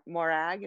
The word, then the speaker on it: Morag: she said finally my thoughts turned to morag is that how you pronounce her name yeah.